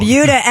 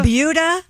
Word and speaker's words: Buda: It's called the Buda... – Buda. – Skip over. Buda sounds like Peter Wolf. – It's spelled B-U-D-A, and I looked it up. It is pronounced like beauty.